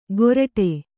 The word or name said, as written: ಗೋರಟೆ